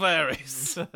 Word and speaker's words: Ares: He's like, "All right, Ares, I'm sorry. Didn't mean to. I just got a bit too big for riches." – You're not saying it right. It's the birds of Ares.